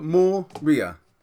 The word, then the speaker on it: Moria: Like, two syllable. Moria. Easy. – Yeah, yeah. Moriah. It's easy. Just say it how it's spelled. – R-I-A anyway.